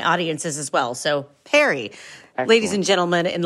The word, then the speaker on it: perry: How do you pronounce it again? Perry? – Perry.